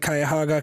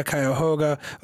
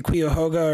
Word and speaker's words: Cuyahoga: Your first clue that it's a fake, the guy can't pronounce the name of the county properly. I mean, okay, it's debated, is it Cuyahoga, Cuyahoga? Okay, but when you say Cuyahoga or something, yeah, it's like, no, that's not even close, man.